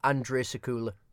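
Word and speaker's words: Andrzej Sekuła: I'm going to say it's pronounced Andre Sekula.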